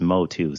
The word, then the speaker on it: Motu: Uh, so it's funny because I, from what I'm hearing, um, the community, the way they pronounce um, pronounce it, it's Motu.